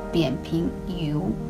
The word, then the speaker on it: 扁平疣: 所以今天来跟大家讲一下什么叫扁平疣。啊，这个词发音是二声，还挺有点靠口的。